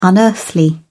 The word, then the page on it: unearthly: British English